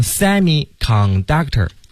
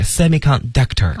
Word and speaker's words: semiconductor: 对，那这个词呢大家不要读成 semiconductor 啊，它的那个重音呢是放到后面，所以是 semiconductor 啊、哦、 semiconductor。